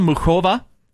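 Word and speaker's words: Muchová: taking down the 10-seed Karolina Muchova in three sets.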